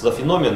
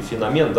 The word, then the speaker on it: феномен: Что это за феномен или феномен, да, слово с двойным ударением, как его оценивать.